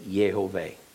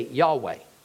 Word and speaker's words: Yehovah: I pronounce Yud-Heh-Vav-Heh Yehovah. My research says it's a three-syllable word. It's most common to pronounce it as among, at least particularly among Christians, because Jews don't generally pronounce it at all. Think about that for a minute. Among Gentile Christians, love to say Yahweh. That doesn't fit the grammar, but I can't say that it's wrong.